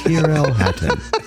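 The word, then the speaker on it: Tyrrell Hatton: I remember we had issues with his name yesterday, but I do believe it's Tyrell Hatton.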